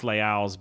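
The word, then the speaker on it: Les Halles: It's pronounced Leal's, but it's spelled Lesmalles.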